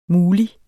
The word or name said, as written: MULIGT